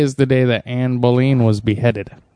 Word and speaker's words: Boleyn: I'm pretty sure it's pronounced Boleyn.